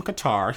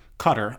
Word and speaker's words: Qatar: He's from Qatar. He was like some billionaire cutter. That's how you say it.